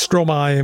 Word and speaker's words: Stromae: Då vill du också Stromae med De Fleur.